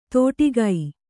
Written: ತೋಟಿಗೈ

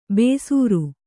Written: ಬೇಸೂರು

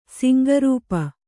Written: ಸಿಂಗರೂಪ

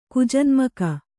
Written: ಕುಜನ್ಮಕ

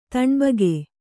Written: ತಣ್ಬಗೆ

